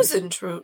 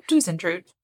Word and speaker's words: Huisentruit: Yes, I looked that up and I spelled it phonetically for all of us. You're welcome. – Huisentruit. – Huisentruit.